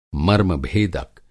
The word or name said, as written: मर्मभेदक